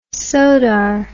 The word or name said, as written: sodar